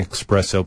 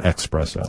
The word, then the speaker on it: espresso: No, espresso is not on the list, although if you're saying espresso, please know you're saying it wrong. You want to go with espresso, not expresso.